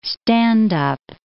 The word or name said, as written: stand up